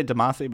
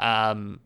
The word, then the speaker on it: Damacy: Did I say that right? – I always say Damacy, but I don't Damacy. – Know. – Damacy. You're probably right.